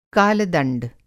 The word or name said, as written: काल-दंड